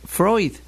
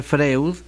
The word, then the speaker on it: Freud: La pronunciación de su apellido siempre es Freud. Si lo pronunciamos como se escribe, Freud, quedaremos en ridículo.